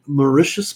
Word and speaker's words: Mauritius: an adventure script called The Mauritius Penny. Am I pronouncing that correctly? Does everybody know how to pronounce that? I'm the only one who doesn't. – You nailed it.